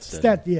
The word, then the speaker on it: Stetson: 14 teams are participating in that, and I think a team named Stenson. – Stetson. – Yeah, that's how you pronounce it. S-T-E-T. – Yeah, just like the cowboy hat, Stetson.